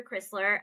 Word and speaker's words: Krisler: And my name is spelled K-R-I-S-L-E-R. So Professor Krisler.